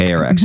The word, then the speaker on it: ARX: Is an acronym ARX or is it ARC? – It's ARX. – ARX.